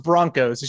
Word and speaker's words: Bronchos: I mean, it's pronounced Broncos. It's just spelled differently for some reason.